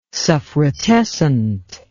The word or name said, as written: suffrutescent